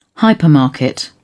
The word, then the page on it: hypermarket: British English